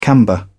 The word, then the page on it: camber: British English